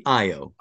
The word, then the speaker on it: Io: Is she pronounced Io? – Usually Io. – Io, sorry. – At least in American English.